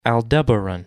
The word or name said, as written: Aldebaran